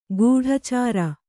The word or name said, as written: ಕಡೆಚೋರಿ